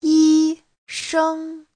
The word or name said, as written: yi1sheng1